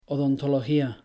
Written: odontología

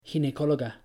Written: ginecóloga

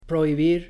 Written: prohibir

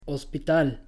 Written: hospital

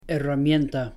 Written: herramienta

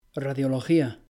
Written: radiología

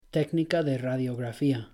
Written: técnica de radiografía

